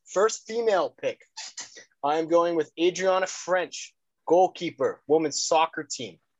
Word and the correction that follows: Franch: Sorry, not French, Franch.